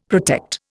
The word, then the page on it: protect: Indian